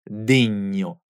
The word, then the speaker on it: degno: Cuidado à pronúncia da palavra denho no singular e denhi no plural, que tem esse grupo nha.